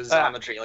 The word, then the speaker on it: Xometry: Think Xerox, so Xometry, like a Z.